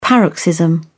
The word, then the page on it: paroxysm: British English